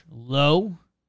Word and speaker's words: Lowe: Jimmy mentioned his teammate Josh Lowe. That's how we pronounce that one.